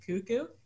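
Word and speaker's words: Koekkoek: how the hell that name is spelt the way it is and you pronounce it Cuckoo, I will never understand.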